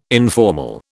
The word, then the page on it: informal: British English